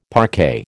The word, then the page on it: parquet: American English